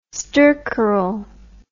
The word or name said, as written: stercoral